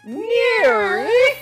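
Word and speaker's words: NIIRYF: Those are the letters for the name of the show, and it's pronounced Near.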